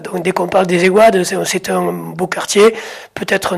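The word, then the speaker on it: Ayguades: Comment prononcez-vous d'ailleurs à Gruissant? Vous dites les EGAD, les AIGAD? – Les EGAD. – Les EGAD.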